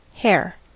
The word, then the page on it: hare: American English